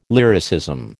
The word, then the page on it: lyricism: American English